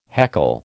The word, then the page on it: heckle: American English